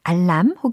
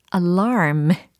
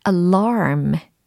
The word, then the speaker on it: alarm: 알람 혹은 경보음에 해당하는 발음을 과연 어떻게 하면 정확하게 묘사할 수 있을까요? 알람이 아니라 alarm 이게 되겠습니다. alarm 해보세요. 좀 우아한 알람의 느낌이 나지 않습니까? alarm, alarm, alarm 좋아요.